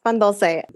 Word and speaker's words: pan dulce: And same question, but regarding I was saying pan dulce, but you pronounce it differently. Pan dulce? – Yes, pan dulce.